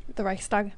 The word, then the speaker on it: Reichstag: Do you know about, well, no, Nazi Germany, the burning of the how do you pronounce it, Reichstag? – The Reichstag, yeah. – Reichstag, yeah.